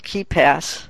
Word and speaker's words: KeePass: And so there is a real delicate balance that occurs, and there is actually a program out there called KeePass. And it's spelled kind of weird. It is not KeyPass. It's KeePass.